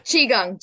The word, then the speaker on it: Qigong: How do you say it? – Qigong. – Qigong.